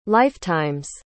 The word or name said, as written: lifetime's